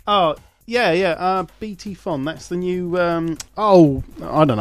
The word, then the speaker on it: BT Fon: No, you've mispronounced it. Phone. – F-O-N. – No, it's silent P-H. – BT Fon, F-O-N.